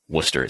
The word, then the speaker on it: Worcester: Worcester is how you pronounce that city in Massachusetts.